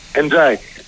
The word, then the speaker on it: Εντζάι: Έχουμε καταλήξει σε αυτή την προφορά. – Ναι, το είπε ο ίδιος, νομίζω. – Ο ίδιος θα βγει. Εντάξει. – Θυμάσαι, Παναγιώτη, έναν Εντζάι που είχε παίρνει το Πανελλήνιος κάποτε. – Θυμάμαι. – Τον λέγαμε Ενδιαγέστη. – Ναι, θυμάμαι. – Βγήκε μαζί από την Εντζάι. Προφανώς αυτό έτσι προφέρεται. Εντζάι.